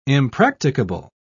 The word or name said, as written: impracticable